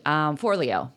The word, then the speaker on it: Forleo: at the conference, who's wonderful, and Brene Brown and Rob Bell and Marie Forleo. I hope I'm saying that right, Forleo. And Forleo is how you say it, sorry.